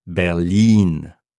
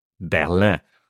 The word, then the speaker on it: Berlin: Par exemple, en allemand, la ville de Berlin se prononce avec une voyelle longue à la fin. Berlin. Donc, on ne dit pas Berlin. On ne dit pas Berlin. On dit Berlin.